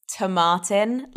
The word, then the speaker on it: Tomatin: Apparently it's Tomatin, like tomatoes.